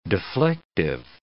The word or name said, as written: deflective